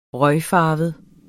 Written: røgfarvet

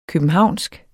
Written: københavnsk

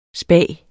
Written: spag

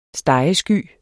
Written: stegesky

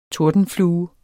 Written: tordenflue

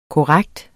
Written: korrekt

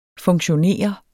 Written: FUNKTIONERET

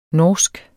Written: norsk

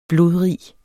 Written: blodrig

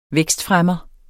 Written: vækstfremmer

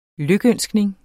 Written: lykønskning

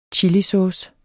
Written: chilisauce

